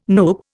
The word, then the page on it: NUPE: Indian